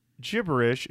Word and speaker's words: gibberish: Jason Andrew Goodman said, FYI, gibberish is pronounced with a soft G sound.